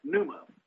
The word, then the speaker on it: pneuma: The second stem is new. It's spelled P-N-E-U. You get the word pneumatic from that Greek word. The word for spirit is pneuma, which is spelled the same, just adds M-A at the end, or P-N-E-U-M-A, pneuma, spirit.